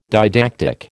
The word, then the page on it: didactic: American English